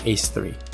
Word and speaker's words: A3: This is Dance Dance Revolution A3, which is pronounced Ace3.